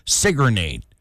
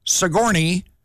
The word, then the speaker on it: Sigourney: Being from Iowa, where we mispronounce town names, I always want to say Sigourney. But her name is Sigourney Weaver.